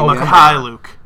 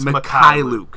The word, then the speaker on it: Mykhailiuk: Oh, Mykhailiuk. It's Mykhailiuk. – Come on, Brennan. Mykhailiuk.